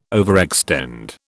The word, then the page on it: overextend: British English